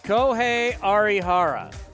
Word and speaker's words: Kohei Arihara: I haven't looked at the guide on how to say it on Baseball Reference, but it says Kohei Arihara is what it says.